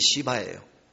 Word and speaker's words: 시바: 그 이름이 여러분들이 들으면 금방 안 잊어버릴 텐데 이름이 시바예요. 발음을 세게 하면 안 돼요. 부드럽게 시바.